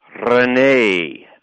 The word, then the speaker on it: René: Well, that's a tough one, but it's René. It's got an accent over the E.